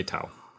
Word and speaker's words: Taaw: Is it Tao? T-A-A-W? – So. Yeah, I would say Tao.